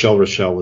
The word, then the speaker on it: Rachel: It was Oliver, Funny Girl, The Lion in Winter, Rachel, and Romeo and Juliet. – Yeah, and, well, I think it's Rochelle is how they pronounced it, but yeah.